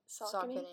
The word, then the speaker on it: Saucony: I feel like I'm not experienced, but I like Brooks in Saucony. Is that how you say it? – Saucony. – Okay, yeah. – That's what I thought.